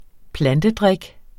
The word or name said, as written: plantedrik